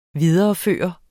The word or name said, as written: videreføre